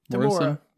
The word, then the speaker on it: Temuera: Temuera.